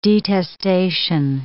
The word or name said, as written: detestation